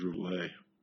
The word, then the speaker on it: Rouvelot: So I picked Peter, and I took my mom's maiden name, which is Rouvelot. Well, the French pronunciation of Rouvelot is Rouvelet. The T is silent.